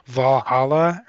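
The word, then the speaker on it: VA-11 Hall-A: But I did play a game similar called Valhalla, or it's spelled like V-A11ala.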